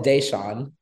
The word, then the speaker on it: Deshaun: Oh, it's Deshaun.